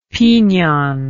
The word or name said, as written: pignon